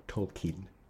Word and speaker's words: Tolkien: Did I mispronounce? – Yes. Well, is it it's J.R.R. Tolkien. – Tolkien? – Tolkien. – Oh, it's Tolkien.